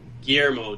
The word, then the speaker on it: Guillermo: Guillermo Del Toro. We probably butchered that name, but... – as far as I know, that's pronounced right, but I'm not 100%.